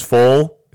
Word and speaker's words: Foal: Yeah, well, trust me, my last name's Foal. It rhymes with asshole, okay?